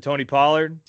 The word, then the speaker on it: Tony Pollard: Is that how you pronounce it, Tony Pollard? – Yep.